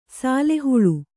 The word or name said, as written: ಸಾಲೆಹುಳು